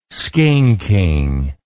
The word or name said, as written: skinking